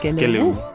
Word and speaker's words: Skelewu: Is that how you say it, really? – Yes, Skelewu.